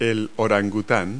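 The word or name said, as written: orangutan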